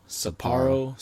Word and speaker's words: Sapporo: Sapporo.